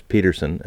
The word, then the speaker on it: Pettersson: Basically, Elias Peterson, and that's how he wants it pronounced, by the way, Peterson. – Even though there's people say Pettersson. – Pettersson, yeah, that's wrong. – Yeah. – They asked him what he wanted, and he he's told the reporters how he would prefer his name be pronounced, and it's Peterson.